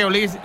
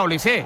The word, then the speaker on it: Olise: Es decir, si Mbappé es Mbappé, Dembélé es Dembélé y Benzema es Benzema, pues digo yo que Olis será Olise.